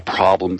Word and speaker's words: Problem: And the problem of living was really a problem for the German people at the time. By Lebensproblem, problem, in other words, that's just how it's pronounced in German, problem.